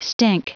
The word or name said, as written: stink